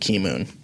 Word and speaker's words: Keemun: Yeah, but going back to the thing, not straying, blood circulation, I would recommend a black tea, specifically like Key Moon. – How do you spell that? – K-E-E-M-U-N.